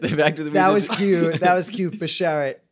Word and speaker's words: beshert: It's beshert.